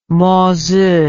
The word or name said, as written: Mozi